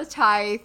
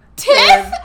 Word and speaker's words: tithe: Put something in the tithe. Yes. Please. – Is that how you pronounce that word? – Tithe. Yes. – Welp, I've been pronouncing that. Tell me. Tiff? – I do this a lot.